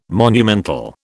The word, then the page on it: monumental: British English